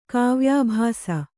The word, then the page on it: ಕಾವ್ಯಾಭಾಸ: Kannada